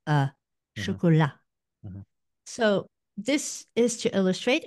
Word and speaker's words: chocolat: So now the lengthening of the last syllable of chocolat is no longer there. It's j'aime le chocolat noir.